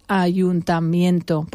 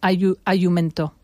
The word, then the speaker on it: ayuntamiento: Me acuerdo mi madre practicando decir ayuntamiento, porque casi todos los ingleses que yo conozco dicen ayumento.